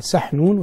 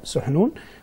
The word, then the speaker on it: سحنون: وسحنون اسم طائر معروف بحدة البصر وذكائه المفرط، فسمي سحنون، لقب عبد السلام هذا رحمه الله بسحنون، وتنطق بضم السين وفتحها، يقال سحنون ويقال سحنون، والأشهر في الاستعمال بالضم سحنون، وصفا له بهذا الطائر.